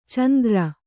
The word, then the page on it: ಚಾಂದ್ರ: cāndra